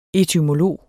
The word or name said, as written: etymolog